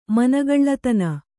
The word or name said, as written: ಮನಗಳ್ಳತನ